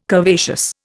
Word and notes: curvaceous: Indian

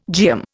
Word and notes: geum: Indian

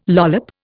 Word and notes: lollop: Indian

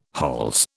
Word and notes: hols: British English

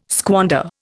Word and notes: squander: Indian

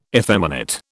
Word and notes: effeminate: British English

